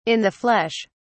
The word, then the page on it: in the flesh: British English